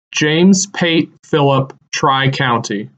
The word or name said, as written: James “Pate” Philip Tri-County